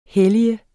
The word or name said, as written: hellige